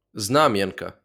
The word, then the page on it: Знам'янка: [ˈznɑmjɐnkɐ]; Ukrainian